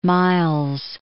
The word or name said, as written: Miles